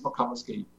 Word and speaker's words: McComiskey: It's a relatively unusual Irish name, but yeah, James McComiskey. Actually, funny enough, I believe in Chicago, there's a baseball park. The way the Americans pronounce it would be Mac Comiskey.